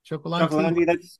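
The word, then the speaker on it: Cioccolanti: Other guys like, I don't know, how do you pronounce his name? Steve Chocolanti? – Chocolandi, that's it.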